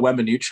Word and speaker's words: Weminuche: the Weminuche high route.